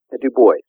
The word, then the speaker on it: Du Bois: Is it Du Bois or Du Bois? – Du Bois. – Du Bois. Yeah, I've heard people pronounce it differently, and I kind of go the French route, but Du Bois.